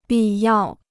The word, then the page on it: 必要: bì yào